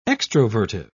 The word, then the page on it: extrovertive: British English